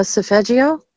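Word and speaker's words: Solfeggio: How do you say that word? – The sophagio? – Yes. Thank you.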